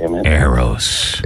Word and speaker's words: Eros: You gotta say Eros.